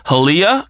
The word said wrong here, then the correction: Hialeah: I think is how you pronounce it. – Oh. – Florida. – Oh, yeah. – Hialeah. – It's Hialeah.